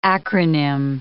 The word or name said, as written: acronym